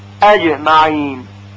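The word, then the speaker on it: أجمعين: مثلا میگوییم اجمعین اج اج، باید خیلی توجه کرد که جیم ساکن فتحه پیدا نکنه، مثلا نشه اجمعین اج، و همینطور کسره اج اج یا ضمه اجو اجو، بلکه به طور ساکن تلفظ بشه اجمعین اجمعین.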